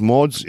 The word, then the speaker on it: Mads: Der übrigens Mods irgendwie so ausgesprochen wird. Mads Mikkelsen.